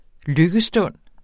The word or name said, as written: lykkestund